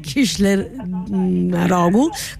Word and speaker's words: Kılıçdaroğlu: Kılıçdaroğlu